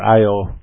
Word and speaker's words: Io: Let's first talk about the moons of Jupiter, especially the innermost moon called Io, spelled I-O. The two letters Io, but Io is the way it's pronounced.